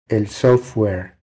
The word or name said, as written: El software